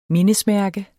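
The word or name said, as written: MINDESMÆRKES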